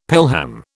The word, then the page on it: pelham: British English